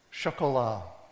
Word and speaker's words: Chocolat: Chocolat, as in hotel chocolat.